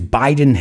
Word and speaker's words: biding: Joe Biden is biding his time. Hombre, es biding, pero acuérdate, americanos no pronunciamos la G. Entonces suena como el nombre del presidente.